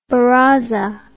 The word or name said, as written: baraza